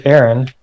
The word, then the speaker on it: Aeron: Well, my name is Aeron, and I changed the way that my name is spelled when I got married.